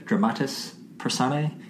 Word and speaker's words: dramatis personae: Dramatis personae.